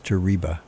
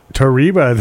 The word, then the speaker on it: Tariba: And also on Sunday, from 6 to 7 p.m., we have a show by a young man named Joey Terabina. – I think it's pronounced Tariba. – Tariba, there you go. Thank you. You're right. Joey Tariba out of Bernie.